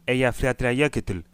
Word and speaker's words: Eyjafjallajökull: Ich habe extra im Internet geguckt, wie das Ding ausgesprochen wird. Aber wir machen es nochmal. Also, Viele von euch erinnern sich wahrscheinlich noch daran an den Ausbruch des Eyjafjallajökull. So nämlich.